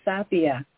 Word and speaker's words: Sapia: Sapia.